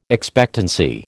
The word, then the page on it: expectancy: American English